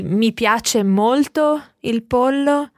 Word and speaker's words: pollo: Just watch your pronunciation because, of course, in Spanish it's pronounced slightly differently, but it's written exactly the same. Il pollo.